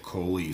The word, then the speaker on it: Cholie: he posted it, so K-O-L-I-E. Oh, nice. Okay. So it's spelled C-H-O-L-I-E, but pronounced like Coley, so K-O.